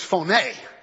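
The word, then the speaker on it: phoné (φωνή): short term, my brothers, it's P-H-O-N-E. Actually, the pronunciation is phoné. we English speaking people call it phone.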